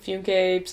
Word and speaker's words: Funke: I don't really know how to say her last name. Funke, Funke, something. – Funke! – But she's German, so that's why I don't know.